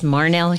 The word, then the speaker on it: Marhnelle: My name is Marnell Hibbard, and the name Marnell is unique. It's spelled M-A-R-H-N-E-L-L-E, and I don't think I really came into owning it until probably 15 years ago.